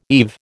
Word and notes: eve: American English

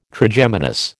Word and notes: Trigeminus: American English

